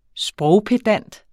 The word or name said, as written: sprogpedant